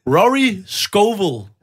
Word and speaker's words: Rory Scovel: Rory Scovel. Rory Scovel. – Hey, der var ja, ja. Som, øh, som... jeg siger. Du skal bare sige det ligesom Scooby-Doo. – Rory Scovel.